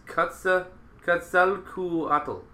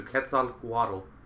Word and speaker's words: Quetzalcoatl: It's Quetzalcoatl. So yeah, like koala, but Quetzal. Okay, so Quetzalcoatl.